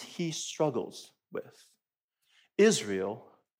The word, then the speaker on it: Israel: This is the word Israel in Hebrew. It's pronounced Israel.